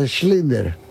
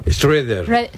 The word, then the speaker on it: Schrödinger: O sea, lo que contaba yo el otro día, del el gato de Schrödinger. – ¿El gato? – Schrödinger. – Schrödinger. – Schrödinger.